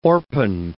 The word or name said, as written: orpine